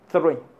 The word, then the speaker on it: three: Y le dije, sonido TH sí hay. Cuando pronunciamos así, así, three, three, three. Entonces es number three, number three, three.